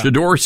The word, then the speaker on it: Shedeur: So the headline in Fox is, and it's Shedeur. Is that how you say his name? – Yeah. – Shedeur Sanders.